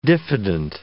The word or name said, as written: diffident